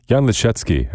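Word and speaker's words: Jan Lisiecki: Starting our morning with Chopin, a prelude in C-sharp minor, Jan Liszewski at the piano here on WRTI. Somehow I didn't know already, just slipped my mind that he's Canadian. Fortunately, he pronounces his name in the original Polish.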